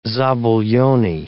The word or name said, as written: zabaglione